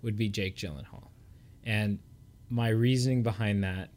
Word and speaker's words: Gyllenhaal: Okay, so the actor's name, and I have trouble pronouncing it, it's Jake Gyllenhaal.